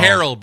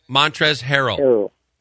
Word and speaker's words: Montrezl Harrell: No, you're saying it wrong. – Harrell, brother. Montrezl Harrell. Okay? – Harrell.